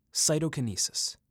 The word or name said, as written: Cytokinesis